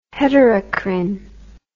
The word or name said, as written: heterocrine